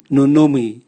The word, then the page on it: nunumwi: Raga